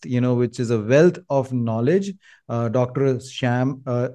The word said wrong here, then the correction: Shay: Again, I mispronounced the name. I'm really sorry. Dr. Sam Shay. I got it right this time.